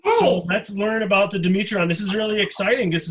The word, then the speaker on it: Dimetrodon: Let's go around, starting with Sophia, and say how we pronounce this creature. – Dimetrodon. – Dimetrodon. – Dimetrodon. – Dimetrodon. – Dimetrodon. Dimetrodon. I think it's probably safe to do it any other way. This is another easy name.